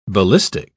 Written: ballistic

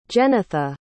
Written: jennitha

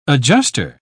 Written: adjuster

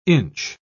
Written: inch

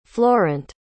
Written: florent

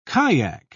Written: kyack

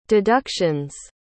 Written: deduction's

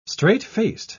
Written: straight-faced

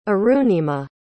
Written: Arunima

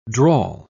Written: drawl

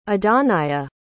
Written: adannaya

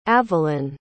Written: avelyn

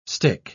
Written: stich

